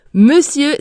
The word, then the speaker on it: monsieur: Monsieur s'écrit avec un O et un N et on prononce le son E et non le son O.